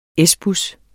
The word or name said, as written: S-bus